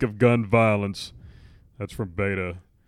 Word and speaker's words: Beto: Beto.